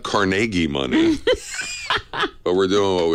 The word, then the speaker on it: Carnegie: Now you're saying it wrong. It's Carnegie. Carnegie Hall.